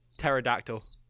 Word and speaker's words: pterodactyl: Pterodactyl. – It doesn't even sound like it begins with P. – But it does. It's got a silent P at the start.